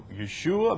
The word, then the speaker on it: Yeshua: You use Yeshua. You use the Hebrew pronunciation. Now, I don't have a problem with, you know, Yeshua.